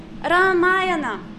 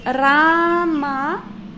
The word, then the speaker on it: Ramayana: So, Ramayana. We have two long syllables, Ramayana. So the first syllable Ra is long because it has a long A in it. Ramayana.